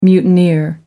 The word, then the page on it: mutineer: American English